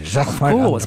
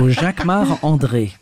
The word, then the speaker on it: Jacquemart-André: You say it like André. A Frenchman. – Jacquemart-André. Because I'm French. So Jacquemart-André.